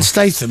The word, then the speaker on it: Statham: Looks like Jason Stratham. Jason Stratham. – Jason Statham, yeah. – Statham. I don't know how you say it.